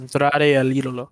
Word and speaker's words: Andrade El Idolo: How do you pronounce it? Andrade El Idolo.